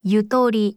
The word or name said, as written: 유도리